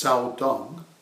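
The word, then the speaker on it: Caodong: It's now pronounced in Chinese Cao Dong school. In Chinese, it used to be pronounced Cao Tung, T-S-A-O-U-T-U-N-G.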